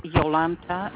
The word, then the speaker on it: Jolanta: Yeah, my name is Yolanta.